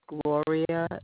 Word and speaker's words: Gloria: Okay, my name is Gloria Dukes. – Can you spell that first and last name for me? – Yes, G-L-O-R-I-A, Gloria. The last name is Dukes, D-U-K-E-S.